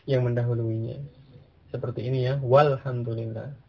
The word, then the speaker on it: walhamdulillah: Sekarang dari tadi, alhamdulillah, tambahkan wawu sebelumnya. Bagaimana membacanya? Walhamdulillah. Ya. Alifnya tidak diucapkan. Jadi langsung ke kelamnya. Ya, walhamdulillah, ya kan?